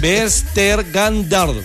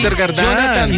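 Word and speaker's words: Vestergaard: Lea cómo se pronuncia. – Bueno, Yannick Bersdagrand. – Eso, así es. – Bersdagrand.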